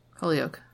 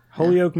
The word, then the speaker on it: Holyoke: But how did you pronounce this? Holyoke? – Holyoke. – Holyoke, yeah.